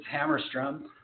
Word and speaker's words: Hammerstrom: It's Hammerstrom.